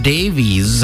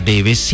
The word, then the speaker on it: Davis: I'm unsure why some of the bigger news networks haven't really shared this story, but turns out Clive Davies, or Clive Davis, as the Western Hemisphere calls him, I think that is in fact the way you pronounce his last name, Clive Davis.